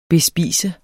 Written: bespise

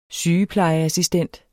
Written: SYGEPLEJEASSISTENT

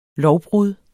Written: lovbrud

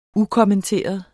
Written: ukommenteret

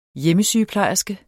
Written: hjemmesygeplejerske

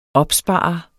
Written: opsparer

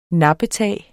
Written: nappetag

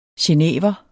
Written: genever

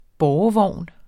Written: bårevogn

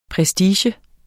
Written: prestige

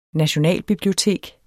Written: nationalbibliotek

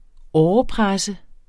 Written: tourniquet

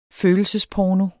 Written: følelsesporno

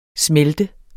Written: smelt

